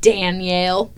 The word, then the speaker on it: Danielle: Danielle. Because I say it like D-E-N instead of D-A-N. – Danielle. – Oh, I thought it was Danielle, too. – Oh, no, it is. My name is Danielle, but in the French world, it's Danielle.